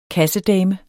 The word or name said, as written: kassedame